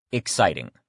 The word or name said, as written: exciting